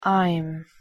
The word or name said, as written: im